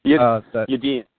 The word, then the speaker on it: Yadin: Yadin.